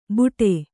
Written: ಬೂಟೆ

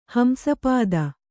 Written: ಹಂಸಪದಿ

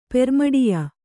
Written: ಪೆರ್ಮಡಿಯ

